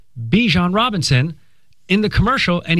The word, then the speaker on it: Bijan: And we got that at the tail end of last season when Bijan Robinson went from Bijan to Bijan.